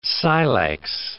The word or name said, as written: silex